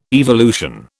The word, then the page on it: evolution: British English